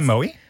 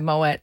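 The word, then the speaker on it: Moët: Did you say Moe? – I said Moet because that's how you say it.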